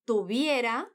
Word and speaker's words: tuviera: Esse verbo conjugado assim, tuviera, se escreve com V. E a Juana fala esse V com o som de B do espanhol. Aquele mais sutil, porque a letra V está entre vogais.